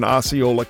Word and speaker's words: Osceola: The original plan called for us to pay a visit to Kissimmee, Florida, down in Osceola County. And the mere fact that I have pronounced both of those names properly tells you that I've ridden through there a time or two.